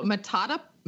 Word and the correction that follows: Motatapu: Motatapu. – I was kind of close. – Motatapu. So, Motatapu. I like that.